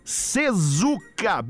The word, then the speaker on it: CESUCA: Arroba Sezuca, bem assim como se fala, C-E-S-U-C-A, Sezuca Oficial, e aproveite para fazer parte da família Sezuca.